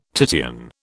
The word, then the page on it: Titian: British English